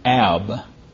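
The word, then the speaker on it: Ab (אב): It would be pronounced something like Ab.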